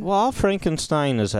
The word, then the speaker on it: Frankenstein: It's pronounced Frankenstein. – Frankenstein, I'm sorry. We'll do our German show.